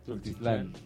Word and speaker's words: Tultitlán: Raztlán en Tultitlán, ¿Tultitlán? ¿Cómo se dice? – Tultitlán.